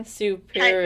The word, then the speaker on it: Suppurativa: Suppurativa. – Yes. – Suppurativa.